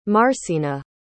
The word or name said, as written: marcena